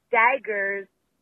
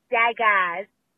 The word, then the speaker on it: daggers: I'm just calling in to tell you that it's pronounced daggers, not daggas.